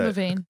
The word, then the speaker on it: Levine: Levine.